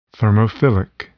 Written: thermophilic

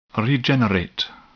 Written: regenerate

